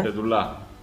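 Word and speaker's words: Pedullà: Ahora, lo que leí, lo que tengo entendido es que según Pedula, o Pedula, no sé cómo se pronuncia, tú me dirás, el periodista. Pedula. Pedula. Según Pedula, Pedula.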